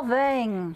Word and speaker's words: Solvang: Slo- Solvang.